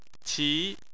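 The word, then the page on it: qi: tone 2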